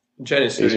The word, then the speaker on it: Janissary: So you have the Janissary, I think it's pronounced. – Janissary, he's pretty cool.